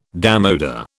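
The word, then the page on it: démodé: British English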